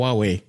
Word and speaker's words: Huawei: It's called Huawei.